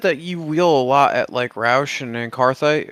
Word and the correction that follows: Anthracite: Anthracite, but yeah.